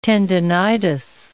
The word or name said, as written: tendonitis